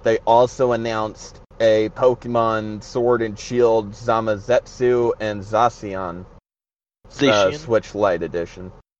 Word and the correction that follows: Zamazenta: Is it Wait, how did you pronounce that? It's Zamazenta.